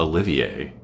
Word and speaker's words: Olivier: What if he brought Oliver Coypel? How do you say that last name? – Mm-hmm. Let's go with that. I think it's also Olivier, because there's two I's in it.